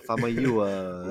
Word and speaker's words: Famuyiwa: Famuyiwa.